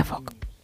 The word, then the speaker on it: folk: Su regla clave es que la letra L es muda, Anda. – con lo cual se pronuncia folk.